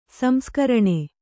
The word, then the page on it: ಸಂಸ್ಕರಣ: samskaraṇa